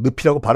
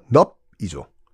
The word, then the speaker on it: 늪: 경상도 사람들은 늪이라고 발음 못하는 거 아시죠? 넙이죠. 이경규 씨가 그렇게 발음하잖아요. 넙에 빠지면 못 나온다.